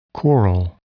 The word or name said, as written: coral